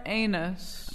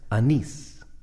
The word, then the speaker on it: anise: We want to buy a lot of star anise. – I believe that's pronounced anise.